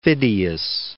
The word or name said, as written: Phidias